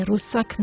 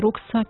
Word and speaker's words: ruksak: Nije rusak, nego ruksak, i tako dalje, i tako dalje.